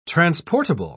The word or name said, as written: transportable